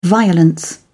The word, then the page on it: violence: British English